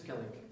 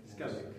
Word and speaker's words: Skellig: Moet ik dat goed uitspreken? – Skellig. – Skellig. Yes.